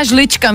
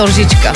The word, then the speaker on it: lžička: kamarádka ještě říká žlička místo lžička.